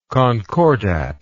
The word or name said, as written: concordatory